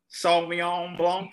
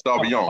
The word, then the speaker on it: Sauvignon Blanc: Nelson Sauvignon Blanc. – Yeah, I like that. Sauvignon.